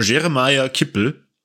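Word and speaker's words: Jeremiah Kipp: Face sehen vom Jeremiah Kippel. Ähm, da bin ich mir ziemlich sicher, dass ich es diesmal richtig ausgesprochen habe. Ja, den hast du gut gemacht, ja.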